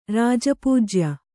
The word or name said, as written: ರಾಜಪೂಜ್ಯ